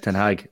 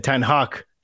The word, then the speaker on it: Ten Hag: I have to compliment your pronunciation of Ten Hag as well. You sound like a man who spent some summers in Amsterdam or something. – I was practicing since Simon Cooper came on, because that was the first thing he said to Joe Malloy. He said, Ten Hag, let's get it right.